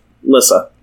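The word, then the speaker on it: Lyssa: Or I as in kit, so lit. Lissa.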